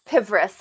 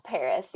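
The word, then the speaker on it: Pvris: Or Pivris, how Mike says it. – Oh, boy. – Is that how you say it? – It's Paris, yeah.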